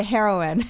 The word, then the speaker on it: heroine: How do you even pronounce that? I was about to say heroin. – Yeah, it is heroin.